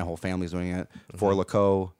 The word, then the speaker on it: Four Loko: It's pronounced For Loco.